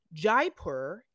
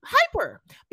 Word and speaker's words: Jaipur: You know what's great about going back to this episode is that I learned that Jaipur is how you say that name. – Oh, because I so what'd you say? What'd you say? – Hyper.